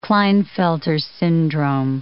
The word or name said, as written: Klinefelter's syndrome